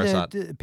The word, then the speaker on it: Parisot: Dean Parisot, is that how you say his name? – Yeah, Dean Parisot.